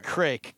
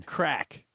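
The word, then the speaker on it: craic: you're pronouncing it incorrectly. – Craik? – It's pronounced... – I can give you the pronunciation. – Yes, give me the pronunciation. – Crack. – Crack.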